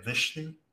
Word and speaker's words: Vishnu: Like, I was looking at Vishnu. – Yeah, that's how you say it.